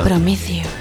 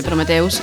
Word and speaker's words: Prometheus: Os metéis conmigo por Prometheus, pero se dice así en inglés. – Sí, sí, sí, lo sé. – Prometheus.